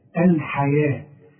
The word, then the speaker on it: الحياة: اشتروا الحياة مكتوبة بالواو ولكن تنطق بالالف ورسم لك الف فوق الواو بيقول لك تنطقها الف كاملة الحياة